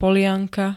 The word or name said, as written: polianka